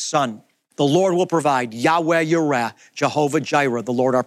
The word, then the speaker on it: Jehovah Jireh: And by the way, that's where we get the phrase Jehovah Jireh, the Lord our provider. It's actually pronounced something closer to Yahweh Yireh.